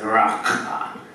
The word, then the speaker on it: raka: So verse 22, as Rach read out to us, he says, if you go around calling people raka, which is pronounced with a sort of a guttural summoning of a spag, you know, that's the sort of way it's done, so raka, which you hear all the time if you go to Israel, it's just the way they speak.